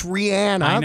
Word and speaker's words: Rihanna: It's Rihanna.